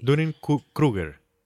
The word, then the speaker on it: Dunning-Kruger: Creo que se dice así. Dunning-Kruger. – Kruger. Como Freddy Krueger. Kruger. Ok. Kruger.